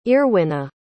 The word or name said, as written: earwyna